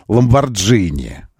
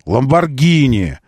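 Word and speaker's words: Lamborghini: У нас Митсубиши называется бренд в России. Нет, они все равно Митсубиси. Нет, все равно у них Ламборджини. Ламборджини, вот это все. Нет, Митсубиши, Ламборгини, Хончи. Вот как все это произносится.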